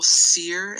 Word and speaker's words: Cyr: If you use my full name, Dallas Michael Sear, and Sear is spelled C like cat, Y is in yes, R is in Robert, Dallas Michael Sear, C-Y-R.